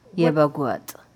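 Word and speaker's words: Yebeg wat: Yebeguat. – Could you spell that? – Y-E-B-E-G. That's yebeg. Then wat is W-A-T.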